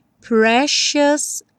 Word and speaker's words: precious: Precious Love. Repara que eu botei um X em cima desse C, porque a pronúncia é precious.